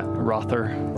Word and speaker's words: Rother: T-O-N. Or I'll try to think of another. Or like Mikkel Rotter. I don't know how you actually pronounce his name. – Mikkel Rotter? – Like Michael Rotter, huh? – Yeah. Rother. – Michael Rother.